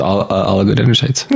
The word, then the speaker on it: oligodendrocytes: That's exactly how it's pronounced, oligodendrocytes.